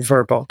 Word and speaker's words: Vrbo: And then so they renamed everything Vrbo, VRBO as in Vrbo. That's kind of how they pronounce it.